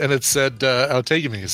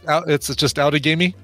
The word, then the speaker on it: Outagamie: And it said Outagamie.